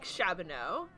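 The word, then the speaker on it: Shabboneau: I think I said that right? Chabonneau, or is it Chabonneau? Yeah. – Chabonneau, yeah.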